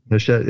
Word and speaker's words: Hachette: Hatchet? – Hachette, yep. – Hachette? Okay. I apologize.